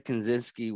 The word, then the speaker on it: Kaczynski: They band together hoping to, and this is more allude to our social media standing now, to the fact of, if Ted Kaczynski was, look at you!